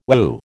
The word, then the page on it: whew: American English